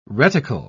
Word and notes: reticle: British English

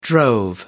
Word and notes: drove: American English

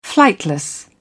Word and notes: flightless: British English

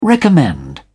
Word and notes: recommend: British English

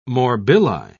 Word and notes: morbilli: British English

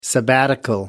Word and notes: sabbatical: American English

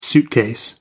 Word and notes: suitcase: American English

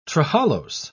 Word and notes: trehalose: British English

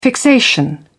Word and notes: fixation: British English

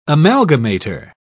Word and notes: amalgamator: British English

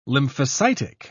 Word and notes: lymphocytic: British English

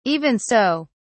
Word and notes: even so: British English

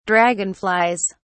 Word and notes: dragonflies: British English

